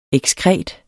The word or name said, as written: ekskret